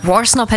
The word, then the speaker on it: Worsnop: Ist das richtig ausgesprochen? Worsnop, ne? – Worsnop hätte ich jetzt auch gesagt, aber ich finde diesen Anfang so mega.